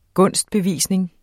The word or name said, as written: GUNSTBEVISNINGERNE